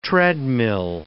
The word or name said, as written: treadmill